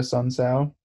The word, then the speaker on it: Sun Tzu: I'm also going to take Sun Tzu. Eric, did I say it right? – Yes, you did.